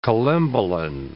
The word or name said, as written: collembolan